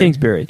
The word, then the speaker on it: Kingsbury: Kingsbury.